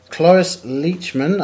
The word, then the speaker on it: Cloris Leachman: Cloris Leachman, I'm probably pronouncing that wrong.